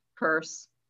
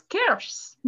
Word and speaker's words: Kerse: there's a, there's a place quite nearby which is called the K E R S E, and I always pronounce it curse. – Right. But no, it's curse.